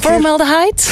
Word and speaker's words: formaldehyde: Formaldehyde? – Ja, dat is perfect uitgesproken eigenlijk.